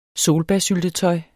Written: solbærsyltetøj

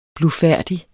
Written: blufærdig

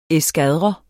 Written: eskadre

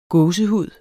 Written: gåsehud

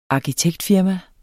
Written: arkitektfirma

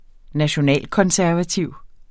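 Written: nationalkonservativ

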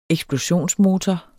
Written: eksplosionsmotor

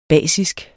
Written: basiske

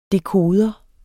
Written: dekoder

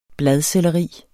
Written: Bladselleri

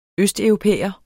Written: østeuropæer

